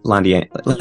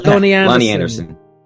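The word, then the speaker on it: Loni Anderson: How would you say it? – Lonnie Anderson. Lonnie Anderson.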